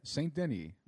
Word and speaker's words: St. Denis: If I get you into the St. Denis, is that how you pronounce it? – Yeah. – St. Denis yeah.